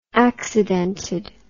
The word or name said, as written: accidented